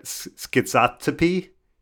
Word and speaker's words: schizotypy: And there's actually, it's a strong predictor of conspiracy theory is something called, I don't know if I'm pronouncing this right, schizotypy, S-C-H-I-Z-O-T-Y-P-Y. So like schizo and tippy, schizotypy. I'll go with that.